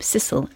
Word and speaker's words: Cecil: who probably pronounced his name Cecil as his family does today, but we'll stick with Cecil for now.